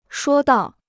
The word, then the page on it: 说道: shuō dao